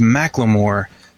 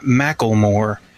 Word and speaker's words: Macklemore: His name is Macklemore, not Macklemore. It's not like tackle box.